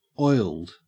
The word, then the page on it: oiled: Australian English